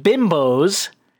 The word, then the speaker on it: Binbows: But anyway, Microsoft Bimbos... That's Binbows for people who are just listening. But pronounced bimbos.